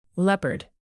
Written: leopard

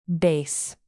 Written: bass

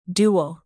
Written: duel

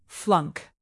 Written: flunk

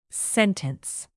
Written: sentence

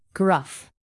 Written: gruff

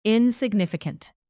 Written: insignificant

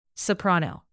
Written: soprano

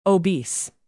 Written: obese

